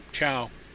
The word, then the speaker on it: Cao: I'd love to see Wang Yi out of prison, and also John, I'm not sure how to pronounce his last name, C-A-O, Chow? – Chow.